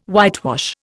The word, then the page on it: whitewash: Indian